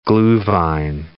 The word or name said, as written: gluhwein